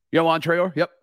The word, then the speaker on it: Yoan Traore: Am I saying that right? – Yoan Traore, yep.